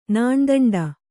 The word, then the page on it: ನಾಣ್ದಂಡ: kannada